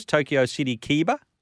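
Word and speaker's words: Tokyo City Keiba: Now, he won the other day at Tokyo race course, Tokyo City Kiba, which is how you pronounce it.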